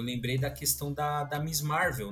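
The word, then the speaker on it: Ms. Marvel: Que, é, que se pronuncia Miss Marvel,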